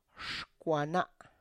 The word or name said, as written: xkwana